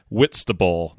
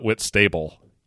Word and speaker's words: Whitstable: It's pronounced Whitstable, not Whitstable, even though it's spelled that way.